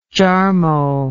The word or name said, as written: Jarmo